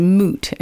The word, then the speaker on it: moot: This week's word is moot and it's spelled M-O-O-T. Mm-hmm. It sounds like something cows would do, doesn't it?